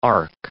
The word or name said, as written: arc